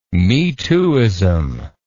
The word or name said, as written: me-tooism